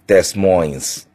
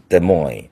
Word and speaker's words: Des Moines: 啊，都干净漂亮，除了底特律那种已经衰败的大城市以外，这个小城叫 Des Moines 啊，但是你一定要念 Des Moines 啊，如果你看到这个小城的名字，但是城虽然小，但是是爱荷华州的首府啊，如果你要不会念，你会念 Des Moines，这个就不行，这两个 S 都不发音，两个词儿的结尾，就像 Illinois 那个。